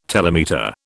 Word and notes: telemeter: British English

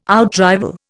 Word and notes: outrival: Indian

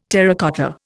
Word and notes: terracotta: Indian

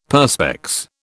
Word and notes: Perspex: British English

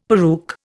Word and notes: peruke: Indian

